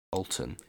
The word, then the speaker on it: Alton: I'm gonna pronounce it Alton, I think, because it's like Alton Towers. It's spelled the same.